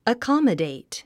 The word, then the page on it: accommodate: əkɑ’mədèit; アコマデェイト